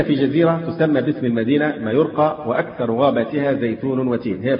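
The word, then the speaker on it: Majorca: اعلموا رحمكم الله أن أصلي من مدينة مايوركا أعادها الله للإسلام، وهي مدينة كبيرة، هي تكتب بالجي مايوركا بس تنطق بالواي مايوركا،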